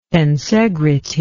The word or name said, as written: tensegrity